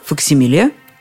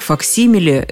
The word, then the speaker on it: факсимиле: Теоретически такое слово есть, но вот произносится оно иначе. Итак, если кто-то сомневается, что человек сам своей рукой поставил подпись, можно предположить, что это факсимиле. Только так факсимиле рекомендуют произносить это слово словари.